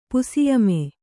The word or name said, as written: ಪುಸಿಯಮೆ